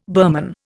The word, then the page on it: Burman: Indian